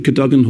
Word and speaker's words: Cadogan: at the, now, correct my pronunciation, because you told me this, it's not pronounced, so I thought it was pronounced, the Cadogan Hall? – Yes.